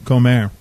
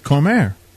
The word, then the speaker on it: Cormier: His name's Comer. – It's not Comer. – It is. How do you spell it? – Cormier. – No, you don't. It's C-O-R-M-I-E-R. Cormier. – But that's how it's pronounced.